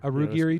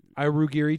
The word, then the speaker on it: Aogiri: But while they're in the middle of having this meeting, or they also talk about, it's the Aogiri tree. Is that how you say it? – Arugiri tree. – Yeah. – Arugiri tree.